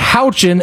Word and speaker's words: Houchin: Houchin is how you pronounce this woman's name. Houchin. – H-A-O. No. Hold on. Go back. H-O-U-C-H-I-N.